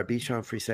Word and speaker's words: Bichon Frise: We lost our Bichon Frise. Am I saying that correctly? – Yes.